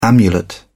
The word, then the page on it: amulet: British English